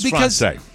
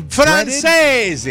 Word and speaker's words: Francese: I thought it was Française. – But it is an Italian-inspired dish. – Really? – So it's a Francese.